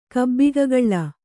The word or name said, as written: ಕಬ್ಬಿಗಗಳ್ಳ